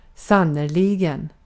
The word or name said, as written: aslında